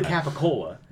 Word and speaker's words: Capicola: There's, like, the reason behind Gabagool is it's really Capicola. – Yeah, but right. It's, like, because certain people from, like, Sicily and southern Italy say, like, G's instead of C's, and they kept the pronunciation that was lost in Italy. There's a whole reason behind it.